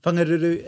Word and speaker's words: Whangaruru: Whangaruru is about an hour north of Whangarei on the east coast.